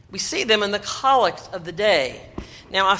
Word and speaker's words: collect: We pronounce it collect in the Episcopal Church for reasons that I have never fully understood, but I imagine find their roots in our love of all things British.